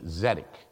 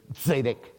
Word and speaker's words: zedek: The word in Hebrew is zedek, Z-E-D-E-K, zedek. If you want to be excruciatingly correct, you pronounce it with a T at the beginning, zedek.